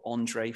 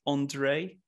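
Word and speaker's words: Endre: On, on, I think it's pronounced Andre. It's it's not it's E N D R E Andre Friedman.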